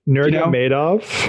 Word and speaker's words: Nurmagomedov: Nurmagomedov. You know, Nurmagomedov.